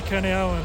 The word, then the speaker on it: Kurniawan: It's Jimmy Kurniawan, and I'm glad to be back, Ben.